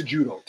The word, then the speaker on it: Cejudo: So when I see the former crown-wearing, cape-wearing lunatic, Henry Cejudo, it's pronounced Cejudo, take my word for it, standing in the cage after the fight where people are cheering, And he's caught up in the moment.